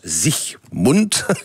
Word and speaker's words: Siegmund: Alors, l'homme fort qui a permis cette victoire, c'est Ulrich Siegmund, en allemand ça se prononce Siegmund.